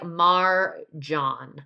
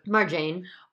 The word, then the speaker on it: Marjane: Uh, just wanted to correct her name's pronunciation. It's kind of like Marjan.